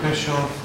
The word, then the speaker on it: Bischoff: My name is Eric Bischoff. My last name is spelled B-I-S-C-H-O-F-F.